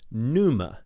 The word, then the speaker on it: pneuma: It's pronounced pneuma, and it's spelled P-N-E-U-M-A, pneuma.